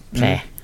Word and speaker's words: Plait: Men jag tänkte börja faktiskt med en bok som jag har här framför mig, som är skriven av Philip Pläää, alltså P-L-A-I-T, Pläää. – Det är nog så han uttalar det.